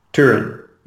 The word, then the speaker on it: Turin: It rhymes with Hurin. – Turin. – Yeah, there you go.